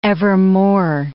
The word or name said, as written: evermore